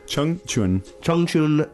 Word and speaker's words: Chongqing: Chongqing, China.